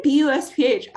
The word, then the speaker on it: BUSPH: I say BUSPH. I say the letters.